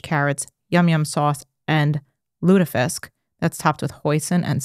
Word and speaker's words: lutefisk: That's how they say it, lutefisk.